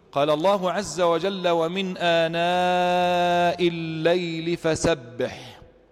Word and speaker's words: آناء: كلمة آناء نحن نكتبها آناء بهمزة بعد الألف على السطر، لكنها في هذه الآية في سورة طه زيدت فيها ياء، ولكنها لا تنطق، ما زيدت إلا في الكتابة فقط، ما زيدت إلا في الكتابة فقط.